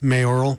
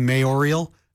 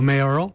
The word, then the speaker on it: mayoral: Well, first, a quick nod to you for being able to pronounce mayoral properly. A bunch of my friends are saying mayorial. – Mayorial. They add an I. – And they're adding the extra syllable in there. So mayoral, props to you.